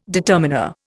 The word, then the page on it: determiner: Indian